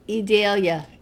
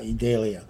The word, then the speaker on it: Idalia: Idalia. – Yeah, Idalia.